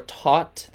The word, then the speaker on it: Thoth: By the way, apparently it's pronounced Tehuti... or taught, that's the academic way to pronounce it, but I just do what I want, so I say Thoth.